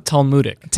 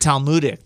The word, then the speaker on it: Talmudic: Talmudic. – Talmudic.